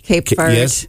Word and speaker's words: Cape Verde: Listen, while I have you there, Cape Verde, what's the correct pronunciation? – I've heard two things. – I've heard three, actually, right? – Cape Verde. – Yes, correct.